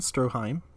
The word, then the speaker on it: Stroheim: Rudolf von Stroheim. Yep. – Stroheim. Okay. Stroheim. Uh, also, well, also to to bring this up again, my subtitles have been also misspelling names specifically. – What do they call Stroheim?